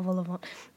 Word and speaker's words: vol-au-vent: God, I love a vol-au-vent. A vol-au-vent? How do you say it? – Vol-au-vent. That shows we're adulting. We're not adults because we don't know how to say vol-au-vent.